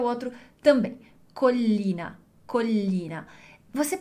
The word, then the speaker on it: Collina: Vamos pronunciar primeiro um L e depois o outro. Collina. Collina. Então, a gente tem a sílaba col, e depois a sílaba li.